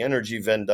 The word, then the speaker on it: Energiewende: And it's a remarkable, I mean, Germany has been holding out as the climate left's heroic nation of the energy transition, the Energiewende, I believe is how it's pronounced in German.